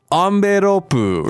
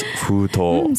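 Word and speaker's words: futo (封筒): Because I was trying to say like, Amberopu or something like no one understood. And then it turns out it's futo. – So desu ne, nihongo de wa futo to iimasu yo.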